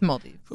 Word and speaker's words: Maldives: Maldives.